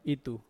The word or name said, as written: itu